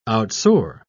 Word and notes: outsoar: British English

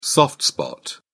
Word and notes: soft spot: British English

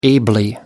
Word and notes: ably: American English

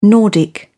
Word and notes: nordic: British English

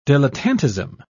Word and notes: dilettantism: British English

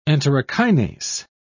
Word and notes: enterokinase: British English

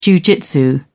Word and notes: jiujitsu: British English

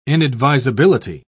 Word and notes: inadvisability: British English

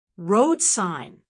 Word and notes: road sign: American English

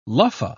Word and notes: luffa: British English

